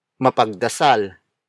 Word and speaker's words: mapagdasal: For example, mapagdasal. Mapagdasal. Mapagdasal. Mapagdasal is spelled as M-A-P-A-G-D-A-S-A-L.